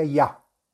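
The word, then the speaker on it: Ya (יה): Se deberá meditar el, salmo, el santo nombre de Yud Hei, que se pronuncia Ya.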